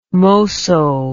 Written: mosso

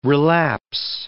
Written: relapse

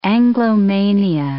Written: Anglomania

